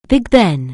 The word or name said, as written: Big Ben